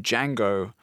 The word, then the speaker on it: Django: It wasn't a black character in that, but so for Tarantino's Django Unchained, it's spelt the same way with the silent D at the beginning.